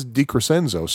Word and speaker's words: DiCrescenzo: He said it Crescenzo, and I always been saying Crescenzo, because I always thought that SC in Romance language derived words would be pronounced like SH, but no, he says DiCrescenzo, so my apologies to Bob.